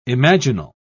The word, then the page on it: imaginal: British English